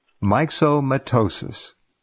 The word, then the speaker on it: myxomatosis: Rabbits in Brazil carry a harmless virus called myxomatosis. Did I say that right? – Excellent.